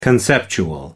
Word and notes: conceptual: American English

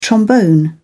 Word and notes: trombone: British English

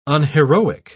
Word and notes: unheroic: British English

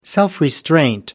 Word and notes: self restraint: American English

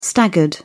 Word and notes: staggered: British English

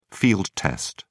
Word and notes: field-test: British English

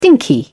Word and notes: dinky: British English

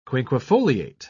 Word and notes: quinquefoliate: British English

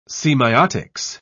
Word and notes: semeiotics: British English

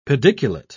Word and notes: pediculate: British English

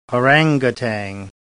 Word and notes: orangutan: British English

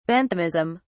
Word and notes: Benthamism: British English

